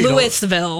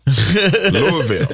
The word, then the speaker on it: Louisville: We were talking Louisville. You know, let's pronounce it correctly. – When I was down there, we had a family reunion down there when I was like 8, and they were saying Louisville. Louisville. And I was like, okay. – That's just because they blend syllables together.